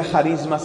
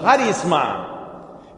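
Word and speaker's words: charisma (χάρισμα): Gracia en griego se dice charis, y de charis viene la palabra charisma, que no se pronuncia charisma, sino charisma.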